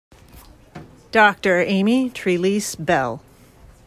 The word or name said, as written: Amy Trelease-Bell